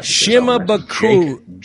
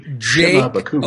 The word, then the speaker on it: Shimabukuro: Shimabukuro. – Jake Shimabukuro. – Okay. – Let's all say it together now on three. Ready? One, two, three. – Shimabukuro.